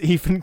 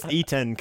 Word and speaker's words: Etan: he wrote like Garfield, and there's a story that apparently Bill Murray thought it was Ethan Coen. he signed up for the film, and it was like, no, it's Etan Cohen. It's spelled differently. It just looks very similar on the page.